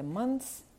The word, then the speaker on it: months: Простий шлях, який абсолютно зробить вашу вимову цих слів близькою до нативної, це просто забити на всі «the», «с» і говорити «months» і «close».